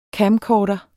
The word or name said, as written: CAMCORDER